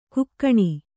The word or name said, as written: ಘಕ್ಕನೆ